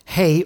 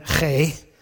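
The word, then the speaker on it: hey (ה): They pronounce it hey, or if you can get the gurgle going, hey, you know, but most of you have problems with that.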